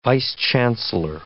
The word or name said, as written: vice-chancellor